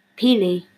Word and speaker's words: Pele: Peely. – Who is that? Hawaiian. Oh, I love Hawaii. That makes sense. – Kelly? – I have no idea. – So it's spelled P-E-L-E, like Lily.